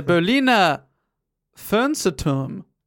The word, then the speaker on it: Berliner Fernsehturm: The Berliner Fernsehturm.